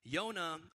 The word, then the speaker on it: Yonah: Yonah is the Hebrew pronunciation of Jonah.